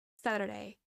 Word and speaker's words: Saturday: This once again is pronounced differently in American and in British English because in British English they have that t sound. But in American English, it just turns into a d sound. Saturday, Saturday.